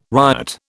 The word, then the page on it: riot: British English